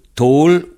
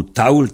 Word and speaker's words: taol: Ça s'écrit T-A-O-L, et ça se prononce comme ça, un est vintain et un léon, dans les deux extrémités. Ailleurs, ça se prononce tôle. Hein? Donc, taoul et tôle.